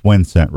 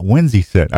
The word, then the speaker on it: Wincent: My guest is Dave Dykema from Fourth Arrow Camera Arms and Wincent. Yeah, not Winsy Scent, Dave. It's Wincent, right? – That is correct. – Yeah. Huh? Dave got on me a couple weeks ago. It's not Winsy Scent.